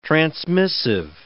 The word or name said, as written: transmissive